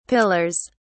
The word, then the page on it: pillars: British English